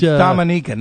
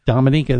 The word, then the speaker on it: Dominica: It's Dominica, not Dominica. – That is correct. Dominica, that's how they say it on the island, Dominica. – It's Dominica there. It's the Dominican Republic, a completely different country.